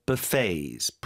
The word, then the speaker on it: buffet: From local eateries to hotel buffets. 뷔페 발음 좀 도와주세요. – Buffet. – Buffet. – Buffet. – Okay. Buffets. 뒤쪽에 강조해 주시고.